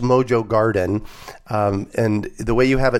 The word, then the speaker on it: Garten: It's Garten.